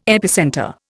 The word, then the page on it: epicentre: Indian